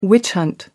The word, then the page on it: witch-hunt: American English